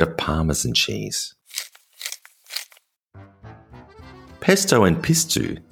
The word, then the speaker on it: pistou: Spelled P-I-S-T-O-U and pronounced pistou, this is a similar sauce in ingredients and the way it's made, but there are two main differences.